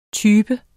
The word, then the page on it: type: [ ˈtyːbə ]; [ ˈtybə ]